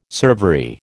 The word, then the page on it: servery: American English